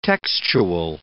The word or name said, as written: textual